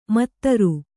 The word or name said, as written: ಮದಿರೆ